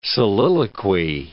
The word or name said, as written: soliloquy